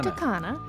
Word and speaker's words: Punta Cana: Punta Cana. – Am I saying that right? – Yes.